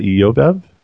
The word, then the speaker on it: Jobab: How would you pronounce Jobab? Is that Eobab? – Yes.